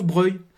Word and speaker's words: Broglie: Ça s'écrit Broglie, mais ça se prononce Breuil.